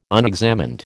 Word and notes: unexamined: American English

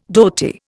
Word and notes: dhoti: Indian

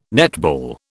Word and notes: netball: British English

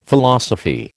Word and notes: Philosophy: American English

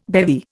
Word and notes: bevy: Indian

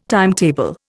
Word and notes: timetable: Indian

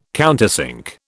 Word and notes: countersink: British English

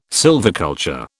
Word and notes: sylviculture: British English

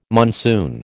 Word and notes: monsoon: American English

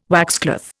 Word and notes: waxcloth: Indian

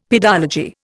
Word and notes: pedology: Indian

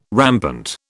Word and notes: rampant: British English

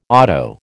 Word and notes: auto: American English

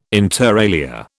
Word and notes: inter alia: British English

